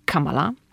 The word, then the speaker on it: Kamala: To znaczy, jeszcze tak powiem, że mąż Kamali Harris i mówię Kamali, bo pamiętam jak kiedyś oglądałam wywiad z Kamalą Harris i ona zwraca uwagę, że jej imię akcentuje się Kamala.